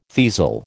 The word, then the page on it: teazel: American English